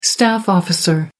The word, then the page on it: staff officer: American English